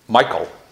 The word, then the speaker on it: Michal: And David marries Saul's daughter, and I hope I'm pronouncing this right, Michal, or is it? Michal, that's how you would pronounce it.